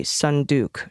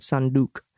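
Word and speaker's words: sunduk (صندوق): There are several words for box, but the closest meaning would be sunduk, short U and long U vowel. Hard K, sound deep in the throat, sunduk.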